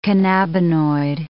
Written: cannabinoid

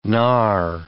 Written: gnar

